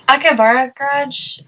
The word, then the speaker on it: Akaibara: It's Akaibara Garage. The I is actually silent when you put it together.